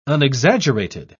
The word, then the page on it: unexaggerated: British English